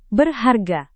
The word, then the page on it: berharga: Indonesian